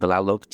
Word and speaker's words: Tlalok: It's pronounced Tlalok, two syllables.